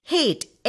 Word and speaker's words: hate: C A T cat, C A R car, C A L L call, A L W A Y S always, A-G-A-I-N, again. A-G-O-N-Y, agony. A-N-S-W-E-R, answer. When you add a silent E to the end of the word, the pronunciation changes again. For example, H-A-T is hat. – പക്ഷെ അതിന്റെ കൂടെ ഒരു E ചേർത്ത് കഴിഞ്ഞാല് hate അല്ല, it becomes hate. – H-A-T-E, hate.